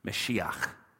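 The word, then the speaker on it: Mashiach (משיח): I want you to tattoo that in your brain because the word anointed one that you see there in Hebrew is pronounced like this. Hear it Mashiach.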